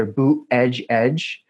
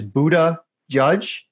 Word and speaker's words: Buttigieg: There seems to be two popular ways of pronouncing his name, either boot edge edge. I prefer the second one, which is Buddha judge, because I think that wisdom and discernment are the two qualities that are most lacking in the executive branch today.